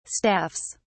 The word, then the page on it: staffs: British English